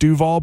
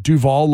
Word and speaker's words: Duval: And it was on every, we go live to Duval, which it's pronounced Duval, but like all the new schoolers call it Duval, like the actor.